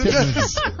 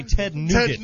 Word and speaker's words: Ted Nugent: Oh, I like Ted Nugent. – Scotch fever. – I'm glad that you pronounced his name correctly, because I've heard people say Ted Nugget.